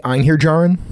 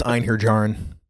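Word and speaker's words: Einherjar: We had three paladins and Anhiriar, which I'm probably mispronouncing, but basically the honored dead of Valhalla that have been ascended. – The Einherjarin? – Okay, that's entirely possible. – I only know that that's a pronunciation because it comes up in the Dresden Files audiobook all the time, and I've heard James Marster say it repeatedly, so that's why I know it's Einherjarin.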